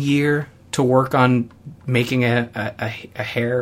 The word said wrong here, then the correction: heir: or how do you say it? – An heir. – An heir, thank you.